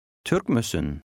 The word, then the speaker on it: Türk müsün: The 2 words are pronounced like one and the stress is on the first syllable. Try it. – Türk müsün?